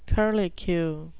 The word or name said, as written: curlicue